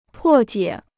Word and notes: 破解: pò jiě

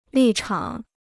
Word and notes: 立场: lì chǎng

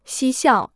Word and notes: 嬉笑: xī xiào

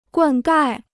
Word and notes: 灌溉: guàn gài

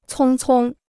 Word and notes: 匆匆: cōng cōng

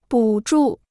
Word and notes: 补助: bǔ zhù

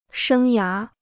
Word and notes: 生涯: shēng yá

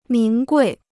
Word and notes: 名贵: míng guì

